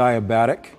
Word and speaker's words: adiabatic: The adiabatic.